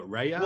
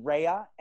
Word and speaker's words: Rea: You know, there's fucking three letters in my name. How difficult is it to say Ray? – Right. – You know? – Yeah. – It is challenging though. I think R-A-Y would be a lot more explanatory for people.